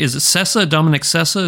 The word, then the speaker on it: Sessa: And that's the same with Dominic Sessa? Is that how you say it? Yeah.